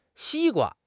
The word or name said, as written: xi1 gua1